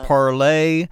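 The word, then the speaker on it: Parler: We also had that conversation with Omar Gayaga on Parlay or Parlor? – Parlay. – Would you like the definitive ruling on the pronunciation? – Well, I guess so, yeah. Well, I know that the founders said it was Parlay, and of course it's from the French to speak.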